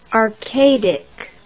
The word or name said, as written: arcadic